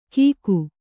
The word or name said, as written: ಗೀಕು